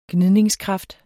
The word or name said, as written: gnide